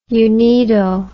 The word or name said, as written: unido